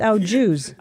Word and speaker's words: au jus: Ouija. – It's au jus. – I thought it was au jus. – Au jus. – Like French.